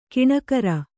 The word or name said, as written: ಗುಣಕಾರ